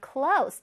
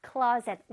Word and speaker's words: clothes: 이걸 원래 발음하면 clothes인데 너무 어렵잖아. clothes. 너무 어렵지 않아요? 혓바닥이 막 날아가겠어요. 그쵸? 자, 이렇게 clothes 안 해도 되고, close라고 발음해도 돼요. 조금 강하게 끝에 close 하면 돼.